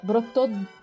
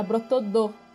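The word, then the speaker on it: Bretodeau: Que ela tava procurando errado porque falaram a pronúncia errada para ela. Que é brotodo, T-O. E, e tinham falado que era brotodo, D-O.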